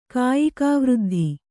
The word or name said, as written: ಕಾಯಿಕಾವೃದ್ಧಿ